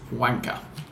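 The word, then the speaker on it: Guanca: He says, for childish reasons, mine is Colón Ford Cristian, and I'm going to spell the surname before I tell English speakers how it's pronounced. It's G-U-A-N-C-A, and it's pronounced Wanka.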